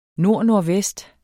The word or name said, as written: nordnordvest